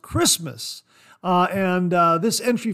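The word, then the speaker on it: Kiritimati: The name is derived from the English word Christmas, written in Gilbertese according to its phonology, in which the combination TI is pronounced S, giving Christmas.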